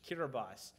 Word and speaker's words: Kiribati: We are missionaries in the country of Kiribati, and I'm pronouncing it right. The T-I is an S in their language, Kiribati.